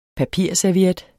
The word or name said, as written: papirserviet